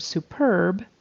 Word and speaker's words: superb: But the word superb is with the accent on the second syllable.